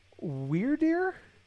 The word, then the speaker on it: Wyrdeer: We have Wyrdeer.